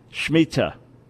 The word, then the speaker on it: Shemitah: Shemitah.